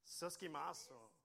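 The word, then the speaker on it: Suskimaso (συσχηματίζω): I'm going to kind of butcher it. It's a sushkemetiso. Maybe I'll come have you read it for me. Just give me a second. How would you pronounce this word? Suskimaso. Wow, that sounds much more Greek.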